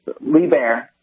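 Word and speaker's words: Liebherr: You're close. It's LeBaer, but it depends on if you ask an American or a German. But if you stick with LeBaer, you're pretty close.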